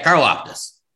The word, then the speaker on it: Karlaftis: I think it's Karlaftis. – Yeah, Karlaftis.